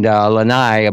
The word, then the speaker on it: Lanai: And Lanai, I believe is the proper pronunciation, is where 75% of the plantation staff lived.